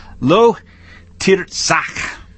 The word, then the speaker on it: lo tirzach: I mispronounced it a while. It's lo tirzach.